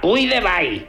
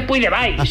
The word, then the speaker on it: Puigdevall: No, no, perdona, Puigdevall. – No, però es pronuncia Puigdevall. És un... – Però oye, ¿me va a discutir mi apellido? – No. – Qué asco de catanazis. Joder, se dice Puigdevall, si me apura, Puigdevall.